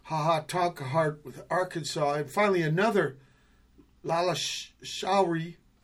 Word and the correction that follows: Lalleshwari: It's lalleshwari. – Am I pronouncing that right?